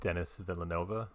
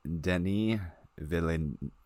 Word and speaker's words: Denis Villeneuve: So the S is probably silent. That makes sense. Because there's only one N, so it's not Denis.